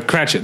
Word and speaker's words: Cratchit: Yeah, but the uh, Tiny Tim uh, Cratchit the Cratchits.